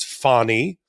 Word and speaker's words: Fani: So let's talk about another name that it's taken me a year to pronounce properly, which is Fonny Willis.